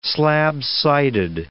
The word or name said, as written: slab-sided